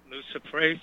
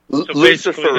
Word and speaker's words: luciferase: luciferase. Am I saying that correctly? – Luciferase.